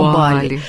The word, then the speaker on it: Бали: Очень сильная, у меня был аналогичный опыт, когда я была на Бали, да, сейчас правильно говорить, на Бали.